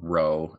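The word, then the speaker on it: rho: No, it's shaped like a P, but it's pronounced R. – Yeah. – It's very confusing when you're in early Greek. – It's a trill. It's a trilled R too, not just.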